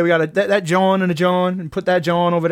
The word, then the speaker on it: jawn: And it's spelled like Lawn. J-A-W-N. – But it's a John. – It is a John.